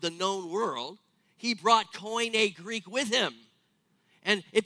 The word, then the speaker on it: Koine: Koine Greek is how you say that word. Koine Greek, which is like a common version of the Greek language.